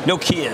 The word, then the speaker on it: Nokia: Really, Nokia is how you're supposed to pronounce. Like IKEA.